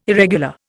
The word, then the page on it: irregular: Indian